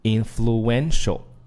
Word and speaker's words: influential: 那么这里面有一个词一定要注意了，就是具有影响力的，就是 influential。 influential 这个词在读音的时候呢，我们一定要注意这个单词的重音位置啊，重音是在哪儿呢？是在这个 n 这个字母 en 发 n 这个音上面，应该是 influential。